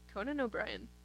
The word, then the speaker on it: Conan O'Brien: Conan O'Brien.